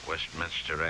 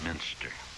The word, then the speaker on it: Westminster: Westminster Abbey. Don't put two I's in Westminster. Quite often you hear Westminster. It isn't. It's Minster.